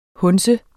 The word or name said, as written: hundse